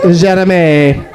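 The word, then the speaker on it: Jérémie: I'm telling you, there's accents over both E's in Jeremy. Jeremy.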